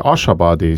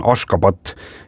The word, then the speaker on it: Ashgabat: Nende pealin, mida mina mäletan tõesti kui Ashabadi, sina ütlesid enne mingi teise nime. Sinna ka sotsite. – Jaa, selle pealinna tänapäevane õige kirjapilt ja hääldus on Ashgabat ja siis 1991.